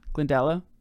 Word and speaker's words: Glendalough: And from Kildare, we're going to go to, on day 8, Glendalough. How do you say that? – Yeah. – Glendalough? – Yeah.